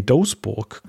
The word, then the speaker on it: Doesburg: Und zwar findet der statt in, ja, wie spricht man es aus? Holländisch D-O-E-S-B-U-R-G. Doesburg, glaube ich. O-E ist, glaube ich, ein O. Also Doesburg.